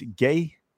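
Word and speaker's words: Gueye: and then if you look also the midfield, and you're going to help me pronounce his name, it's Gay. Gay.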